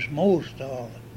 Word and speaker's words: Mosdal: And her maiden name was Mosdal. – Mosdal? – Yeah. – How would you spell that? – M-O-S-D-A-L.